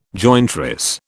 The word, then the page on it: jointress: British English